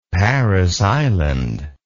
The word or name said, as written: Parris Island